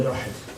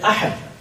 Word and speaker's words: Uhud: Many of us have heard of the word Uhud. Uhud, it can also be pronounced as Uhud. Some say Uhud is better rather than Uhud. Some have also said Ahad.